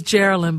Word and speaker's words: Geraldine: Look, you know, my name is Geraldine, by the way. You're Carolyn. So yours is spelled with a K and mine is spelled with a J at the front.